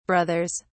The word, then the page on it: brothers: British English